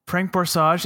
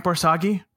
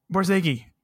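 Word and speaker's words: Borzage: Did you know that Frank Borsage is pronounced Frank Borsagi? – Borsagi.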